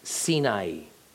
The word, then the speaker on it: Sinai: Right, like in Mount Sinai. In Hebrew, Sinai is actually pronounced Sinai. Sinai.